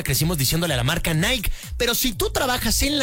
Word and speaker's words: Nike: La realidad es que la marca se pronuncia Nike. Porque viene del griego. Nike, la diosa griega de la victoria.